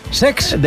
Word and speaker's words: Cesc: El Como de Cesc Fàbregas, que se estrenó... – Lo he dicho mal, es Sex.